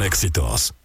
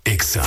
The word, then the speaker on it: Exa FM: No se dicen éxitos, se dice EXA.